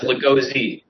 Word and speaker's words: Lagoze: Almost. It's Lagoze.